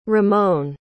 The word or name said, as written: rimon